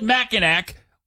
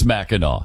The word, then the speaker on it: Mackinac: First of all, It's spelled, why do they spell it Mackinac when it's Mackinac?